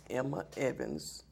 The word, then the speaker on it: Emma Evans: Emma Evans, E-M-M-A, E-V-A-N-S.